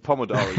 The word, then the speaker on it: Pomodoro: Sorry. I totally mispronounced it. It's Pomodoro. You're right.